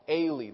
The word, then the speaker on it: Ealy: There was a man by the name of John E-A-L-Y, Ailey. That's the way I pronounce it. It could be Ailey or Ailey.